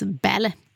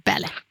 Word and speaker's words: Balle: Ja, och Solveig heter, det stavas B-A-L-L-E, men uttalas Bälle. Bälle.